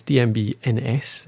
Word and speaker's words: DMB&S: Oh no, sorry, I've said it wrong. DMB&S. We had a little conversation before the recording there about how you pronounce that. Apparently, there's a few different ways to go about it, but the official one is DMB&S, Developing Mountain Biking in Scotland.